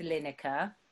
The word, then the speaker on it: Lineker: So I think I remember in that case, I knew I wanted to have lots of rhymes with Lineker or almost rhymes with Lineker. – Is it a compound rhyme they call it? So the Lineker with Winner Sir. – And Dinner Sir.